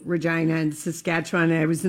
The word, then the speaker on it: Regina: Regina. And the person said to me, oh, no, it's Regina rhymes with vagina.